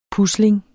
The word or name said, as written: PUSLINGENES